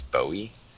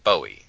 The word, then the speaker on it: Bowie: That's it's spelled Bowie, but regionally they pronounce it Bowie.